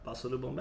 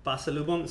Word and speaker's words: pasalubong: The G is silent. – Pasalubong. No, you can say the G.